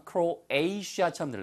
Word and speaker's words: Croatia: 크로아티아의 발음은 지난번에도 말씀드렸습니다. 크로에이시아처럼 들려요. 크로에이시아. 잘못되면 아시아야? 크로에이시아. 예, 그래서 아시아를 아시아 혹은 에이시아라고 발음하거든요.